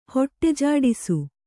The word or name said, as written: ಹೊಟ್ಟೆಜಾಡಿಸು